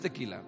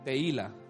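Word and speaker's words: tehilá: Uy, ya encontré cómo alabar al Señor, era No, no, no, no es tequila, es te, teila. Que la H no se dice, teila.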